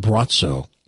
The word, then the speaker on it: Braco: Spelled B-R-A-C-O. – Bratzo. – Spelled B-R-A-C-O, but pronounced Bratzo.